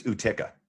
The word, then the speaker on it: Utica: Utica, is that how you say it? – It's just right. That's just right. – Utica? – Utica. – Like when I look at the like Utica, I have Uma Thurman in my head. Like that's how I'm pronouncing it. – I believe it's Utica. – Oh, Utica. Oh, okay, perfect.